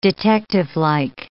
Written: detectivelike

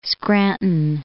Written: Scranton

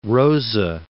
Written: Rosa

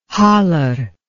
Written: haller

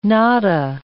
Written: nada

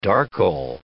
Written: darkle

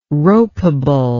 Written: ropeable

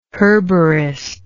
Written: herborist

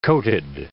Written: coated

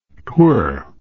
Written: 'twere

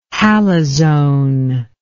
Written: halazone

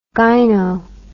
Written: gyno

